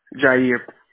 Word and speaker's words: Jahir: Jair. – Jair? – Yes. – Oh, nice. How do you spell that? – J-A-H-I-R. The H is silent.